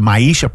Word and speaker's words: Maisha: Maisha Project. Just so you know, it's spelled M-A-I-S-H-A. There you go. Doesn't look like it would say Maisha, but that's how you pronounce it.